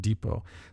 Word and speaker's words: Depot: Ergo Deepot. Or Depot. I'm not, how do you say that name? That word. It's a French word. Depot. It's like Colbert Report. Ergo Depot. We're also sponsored by Ting. T-I-N-G.